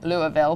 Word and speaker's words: Louisville: So we did this great interview at my favorite hotel in Louisville. Did I pronounce that properly? Louisville.